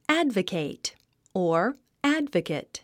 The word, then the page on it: advocate: ǽdvəkèit; アドバケイト